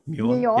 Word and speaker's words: Muon: So is it a Muon? Have I pronounced that right? – Muon. – Yep.